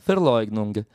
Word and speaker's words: Verleugnung: O Freud vai utilizar um termo em alemão que é o Verleugnung, que a gente escreve com V, Verleugnung, mas se pronuncia Verleugnung.